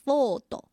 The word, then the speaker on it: Ford: 接下来是四号彼得福特 Peter Ford。那他跟数字有什么关系呢？也就是他的姓福特，福特念作 Ford。那这个日文里面如果要用日式的发音来念英文的四，就念作 four，跟 ford 的这个前半段的这个音呢是一样的，所以呢他就被犯人列为是倒数第四个猎杀的目标哦。